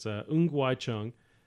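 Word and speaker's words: Ng Wai Chung: So I'm going to pronounce the name the right way. So it's Ngwai Chung.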